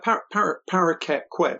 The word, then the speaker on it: Paraquet: Yeah, Paraquet. Yeah, it's actually named after a parakeet, a parrot,